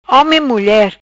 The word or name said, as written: Home e Mulier